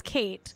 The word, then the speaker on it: Kate: Whatever. Kat. It's Kate, but C-O-T. – It's Kate. – C-O-T, how you spell that?